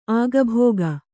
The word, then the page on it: ಅಗಿಬಿಗಿ: agibigi